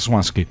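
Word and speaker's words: Śląski: ¿Cómo, se pronunciaba el Slaski? Katowice. – Ah, teatro eh, eh, Swansky. – ¿Es Swansky? – Swansky.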